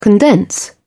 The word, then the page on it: condense: American English